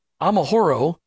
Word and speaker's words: Amahoro: Amahoro Ava. I can't go after that. It's a cooperative. How is it all pronounced? – Uh, you, you got it. Uh, you you got exactly.